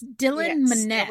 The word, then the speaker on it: Minnette: I have the pronunciation. I had it, like, queued up because last names. It's Dylan Minnette.